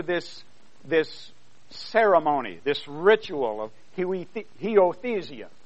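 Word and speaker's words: huiothesia: And the word adoption, in the Greek, the word is heiothesia. Did I say it right? I did? Yes. Heiothesia.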